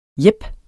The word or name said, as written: jep